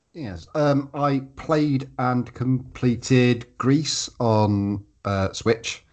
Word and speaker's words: Gris: It's written Gris, but apparently it's pronounced Grease.